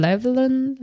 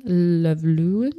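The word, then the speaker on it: Llewellyn: Und dieser wahrscheinlich der Day ausgesprochen, Levelin, Levelin. Ja, genau. Der Gefährliche lebte von 1931 bis 1966 und er ist der berühmteste Quidditch-Spieler der walisischen Mannschaft, den Caerphilly Catapults.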